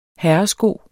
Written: herresko

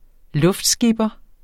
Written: luftskipper

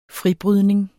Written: fribrydning

